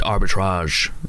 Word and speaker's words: Arbitrage: Apparently it is Arbitrage. – Arbitrage. Yeah. – So you could go for that's the way Danny Lee pronounced it in film 2013 last night. It was Did he? – He went Arbitrage.